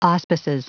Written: auspices

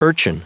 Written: urchin

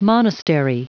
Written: monastery

